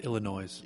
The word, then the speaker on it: Illinois: I believe it's pronounced Illinois.